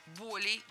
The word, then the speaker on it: боль: Поэтому запоминаем слово «боль» с неподвижным ударением на корне, поэтому всегда ударяем первый слог. «Болей», «болями», «болях».